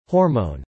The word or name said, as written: hormone